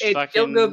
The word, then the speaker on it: Gilgo: You guys put a lot of this, like, I don't know how you say it, Gilgo or Giljo Beach. – Gilgo Beach. That was something that started happening in 2010.